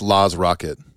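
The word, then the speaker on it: Laaz Rockit: Openers were, how do you say it? Laz Rocket? – I think it's Laz Rocket.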